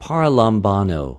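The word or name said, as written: παραλαμβανω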